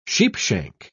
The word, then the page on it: sheepshank: British English